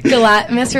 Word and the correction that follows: Galantis: How do you say it? – Galantis. – Galantis.